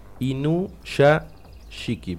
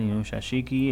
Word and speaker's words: Inuyashiki: Inuyashiki, ponele. – Muy bien, Inuyashiki.